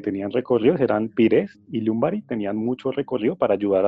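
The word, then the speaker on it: Ljungberg: Recordamos que en esa época el, que las bandas eran Pires, Robert Pires, y, y Lumbari, mal llamado Lundberg, que se llama. Lumbari se pronuncia, el sueco.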